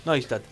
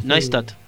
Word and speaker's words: Neustadt: Neustadt. – Es una fresquita. – Muy buena pronunciación del alemán. – Neustadt.